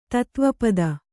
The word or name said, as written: ತತ್ವಪದ